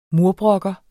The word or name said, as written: murbrok